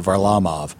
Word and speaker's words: Varlamov: I've been told that's how you pronounce it, Varlamov, which okay.